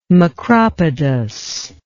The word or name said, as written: macropodous